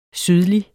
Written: sydlig

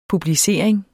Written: publicering

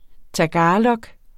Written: tagalog

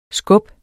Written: skub

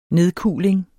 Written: nedkuling

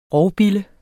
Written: ROVBILLE

